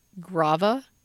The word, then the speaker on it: Gravaa: And I wanted to start with the, I think it's pronounced Grava, G-R-A-V-A-A, tire inflation system.